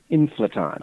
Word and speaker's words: inflaton: And yeah, we put normally it's supposed to remind you of electron, so it's supposed to be inflaton. So it normally be pronounced inflaton.